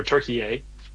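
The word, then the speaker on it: Türkiye: Turkey or Turkey. As the proper pronunciation.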